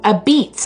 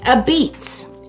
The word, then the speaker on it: apizza: And pronounced a beets, actually. Isn't that funny? It was called a pizza, A-P-I-Z-Z-A, and pronounced a beets, A-B-E-E-T-S.